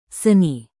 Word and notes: seni: Indonesian

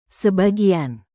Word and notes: sebagian: indonesian

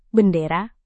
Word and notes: bendera: indonesian

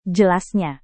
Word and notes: jelasnya: Indonesian

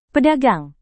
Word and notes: pedagang: Indonesian

